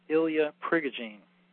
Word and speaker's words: Ilya Prigogine: Now, what's interesting is there was a guy named Ilya Prigogine, and his name is spelled I-L-Y-A-P-R-I-G-O-G-I-N-E, Ilya Prigogine.